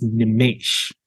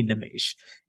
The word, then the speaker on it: Nimesh: So my name is it's an Indian name, and it's actually pronounced Nimesh. So I guess the E is an A sound. So she actually calls me Nimesh.